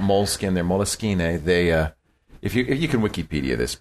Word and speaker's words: Moleskine: Do you like the moleskin? Do you pronounce it moleskin? – Moleskine. Believe it or not.